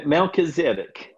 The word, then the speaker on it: Melchizedek: Melchizedek.